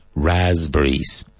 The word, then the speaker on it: raspberries: fresas, strawberries, and frambuesas, raspberries. Now, raspberries is spelt R-A-S-P-B-E-R-R-I-E-S. But it's not raspberries, raspberries. The P is really silent.